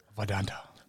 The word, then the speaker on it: Vidanta: And I think that a course like Vidanta, if I'm saying that right, we've gone through a whole episode and I still don't know how to say it. – Vidanta. – Now it sounds Asian.